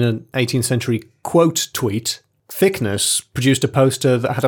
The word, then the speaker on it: Thickness: I swear I didn't pick this story for the names, but Captain, it's actually Thickness, E-S-S-E. And you're always saying, Captain Thickness, it's Thickness. Pronounce it Thickness, please.